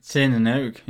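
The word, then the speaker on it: Tir na nÓg: This one's at number 315 on West 39th Street, a bar called Tir na n Óg. Do you know how that's spelled? – Tir na n Óg, yeah. – It's spelled T-I-Fada or N-A-N-O-Fada-G.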